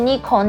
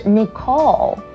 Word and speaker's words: Nicole: 再来这个我超常听到人家念错，就是妮可的英文，就是大家都会念 Nicole Nicole, 但其实它正确的发音是 Nicole Nicole, 它的拼法是 N-I-C-O-L-E。